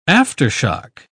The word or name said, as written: aftershock